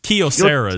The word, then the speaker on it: Kyocera: Kyocera is actually the correct pronunciation, but I don't want to be that guy.